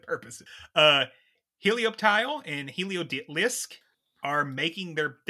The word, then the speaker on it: Helioptile: So it's Helio, like, because it helps, Halo. And Tile, so Helioptile, Helioreptile. – Yep. – Helioptile.